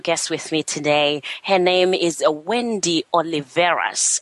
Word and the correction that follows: Oliveras: So, how do you pronounce the last name? Is it Oli- Oli- Oliveras? – It's it's uh, Oliveras. – Oliveras. – Okay.